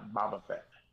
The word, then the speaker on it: Boba Fett: Am I saying that right? Boba Fett? Boba Fett? – Yeah, Boba Fett.